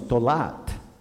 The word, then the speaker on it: tolat: The male is pronounced tola, and the female is tolat.